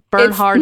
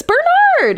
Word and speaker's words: Bernhard: Bernhard Goetz. No, I believe it's Bernard. I believe it's Bernard. – Call him Bernie. – I think the H is silent.